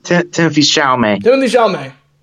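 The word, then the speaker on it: Timothée Chalamet: I can't pronounce his name for the life of me. – Yeah, Timothy Chalamet.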